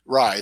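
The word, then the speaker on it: Raih: What I think is the misconception or the biggest issue is that people don't understand that Cliff Kingsbury and David Ray Raw, how do you say his name? – David Rye, the wide receiver coach, have literally been after Kyler Murray for what, 5 years now? – Yes, since he was in high school.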